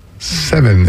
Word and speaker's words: Sevyn: Seven is how she pronounces her name, spelled S-E-V-Y-N, Seven Streeter, and YG, Young Jeezy.